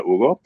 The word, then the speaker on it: Hugo: So his name is probably Hugo, or it sounds like Hugo, the name Hugo, but in Spanish we don't pronounce the H, so Hugo.